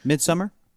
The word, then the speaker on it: Midsommar: Midsommar. It's pronounced Midsommar, really? – Yeah, because it's Swedish.